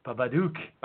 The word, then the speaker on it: Babadook: It's Babadoc. – What? – Is that how you pronounce it? – No. Oh, my God, that's terrible. – How you pronounce it? – Babadook. Babadook, it's been evolving to Babakook.